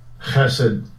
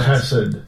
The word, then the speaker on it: Chesed: Strong's H 2617 Chesed. – There you go. – Chesed. – Chesed.